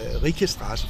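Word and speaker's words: Rykestraße: Så de hedder altså Rikestrasse for eksempel. Det staves med Y, men udtales med fransk I.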